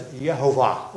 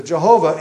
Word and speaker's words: Jehovah: And that name gets pronounced as Yehovah or Jehovah. So Jehovah